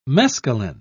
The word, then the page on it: mescalin: British English